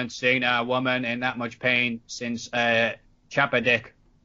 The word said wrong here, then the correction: Chappaquiddick: Chappaquiddick. Chappaquiddick.